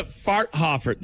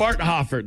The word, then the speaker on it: Farthofer: Lisa Farthofer, that's her name. – Hold on a sec. – Or is it Farthother? – You go from rowing into Farthofer. How do you spell it? – Farthofer. That's her name. Lisa Farthofer. – Spell it for me. – F-A-R-T-H-O-F-E-R.